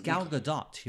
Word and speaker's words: Gadot: It's Gal Gadot.